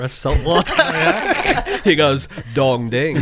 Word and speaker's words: Dong Ding: So if you get it at the right time, you get, for instance, something called Dong Ding Oolong tea, which actually I think it's pronounced Tung Ting, but I like the idea of changing the phrase ding dong to dong ding.